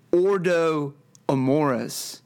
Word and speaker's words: Ordo Amoris: Vance made reference to Ordo Amoris. Am I pronouncing that right, Jeff? – It's about right. Yeah, it depends on if you're using a ecclesiastical Latin or classical Latin, and I don't know enough about either to know the difference.